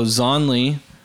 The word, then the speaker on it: Zonli: So Zonli means Z only. That's how it's spelled.